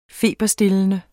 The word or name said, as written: feberstillende